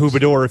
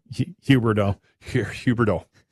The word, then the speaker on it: Huberdeau: Huberdeau, if he's and that looked like a knee. Did I say it wrong again? – Yeah. Huberdeau.